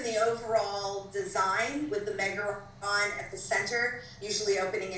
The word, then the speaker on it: Megaron: There's a, I believe you used the term Megatron. Did I say that correctly? – Megaron. – Thank you. – Megaron.